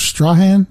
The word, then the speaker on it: Strahan: It's spelled Strahan, and I thought it was Strahan when I first saw it, but people call it Strahan, so I just roll with it.